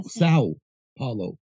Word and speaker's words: São Paulo: Sao Paulo. It's not sand. There's no N in it. It's S-A-O. Sao Paulo.